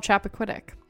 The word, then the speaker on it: Chappaquiddick: But if you were wondering, it was Chappaquiddick.